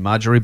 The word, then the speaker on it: Marjorie: Uh, and and I did suggest that that Mark's partner was Marjorie, as in Marjorie, but but spelt with uh, pronounced in the French way with a, with a silent J, or at least a kind of a, a Y sounding J.